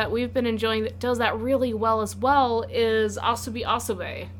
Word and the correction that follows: Asobi Asobase: Asobase. – Asobase. – We've been pronouncing it wrong for like three episodes. – Yeah? – Yes. Asobi Asobase.